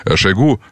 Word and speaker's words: Шойгу: Шойгу вообще, Шойгу, на правильное ударение.